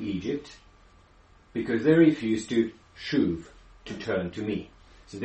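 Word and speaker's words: shuv (שׁוּב): It's English, you say shuv. I don't know what it looks like in Hebrew. It looks like that. Shuv. – And that's how you pronounce it.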